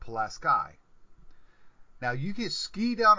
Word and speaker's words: Pulaski: P-U-L-A-S-K-I. S-K-I is ski. Whether it's the English language or the Polish language, it's ski. But no, up here it's sky. Unless you're going down the slopes, because you're not skying down the slopes, you're skiing. they don't understand that difference. It's Pulaski up here. It's Pulaski, New York.